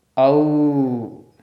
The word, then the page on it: au: Sanskrit